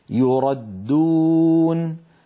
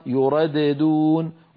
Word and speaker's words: يردون: مثال الخامس: يردون، يردون الدال المشددة، لو نطقنا الدال الأولى مقلقلة لصارت يرددون، ولا يفعل هذا أبدا أحد أبدا، لا من القراء ولا من العرب.